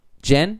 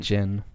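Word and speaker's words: Jyn: And it was cool to see, like, the little backstory thing with Jen. Is that how you pronounce it, or is it Jin? – Jin.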